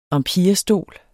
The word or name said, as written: empirestol